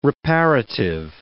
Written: reparative

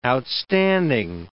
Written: outstanding

